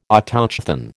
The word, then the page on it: Autochthon: American English